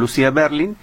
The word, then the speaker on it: Lucia Berlin: Sí, de acuerdo, soy sin acento, Lucia Berlin, sin acento.